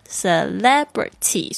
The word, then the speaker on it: celebrity: Foreign F-O-R-E-I-G-N 特别注意一下拼这个字你要记得拼那个 G 哦虽然它是不发音的 F-O-R-E-I-G-N Foreign 然后呢 Celebrity 这个字有点难念注意一下它的重音是在哪里呢 Celebrity